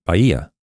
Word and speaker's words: Bahia: And if you look it up on Google, and Google, I was pronouncing Bahia grass, but it says Bahia grass, so it's you don't pronounce the H, it's Bahia grass.